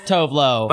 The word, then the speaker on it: Tove Lo: It's just Tove Lo.